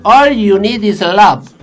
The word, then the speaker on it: All you need is love: Como dijo el maestro Lennon, All show need is love. – No, pero escúcheme, pronuncie bien. All you need is love.